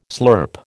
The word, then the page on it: slurp: American English